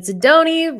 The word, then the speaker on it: Sidoni: Today we've got David Sidoni, rhymes with baloney.